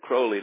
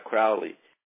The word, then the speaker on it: Crowley: And he's the one who told me you pronounce it Crowley, not Crowley.